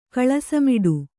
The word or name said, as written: ಕಳಸಮಿಡು